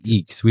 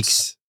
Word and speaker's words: Ickx: It's actually Ickx. We've been saying it wrong the whole time. – Yikes. Sorry for his name's Yikes. Ickx.